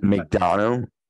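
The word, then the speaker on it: McDonagh: You had Bron Breakker, you had Dragunov, you had JD Madonna, you know, or how do you say that name? Gosh, I can't even say it. – McDonough. McDonough.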